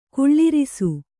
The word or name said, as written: ಕುಳ್ಳಿರಿಸು